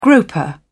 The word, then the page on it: groper: British English